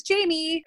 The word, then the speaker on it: Jamee: My podcast is called Coaching with Jamie, and that's Jamie with two E's.